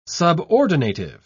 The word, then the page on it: subordinative: British English